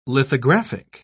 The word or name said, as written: lithographic